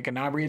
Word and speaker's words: Gnabry: Gnabry. It's weird to have a GN at the beginning of a feels like a silent.